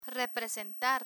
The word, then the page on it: representar: Spanish